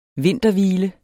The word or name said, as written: vinterhvile